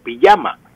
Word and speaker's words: pijama: aunque muchas veces pues escribe, usted la encuentra en libros, en periódicos con J, pijama, pero se pronuncia sin embargo pijama, con Y.